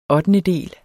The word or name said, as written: ottendedel